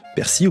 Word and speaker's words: persil: Regardez dans un dictionnaire de référence, vous verrez que la prononciation préconisée est celle sans L. On dit persi, nombril, sourcil.